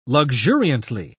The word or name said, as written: luxuriantly